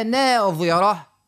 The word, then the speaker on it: ناظرة: يعني نقول وجوه يومئذ ناظرة ضاد اللي هي ناظرة إلى ربها ناظرة ضاء ضاء والأخرى ضاد.